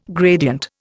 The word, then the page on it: gradient: Indian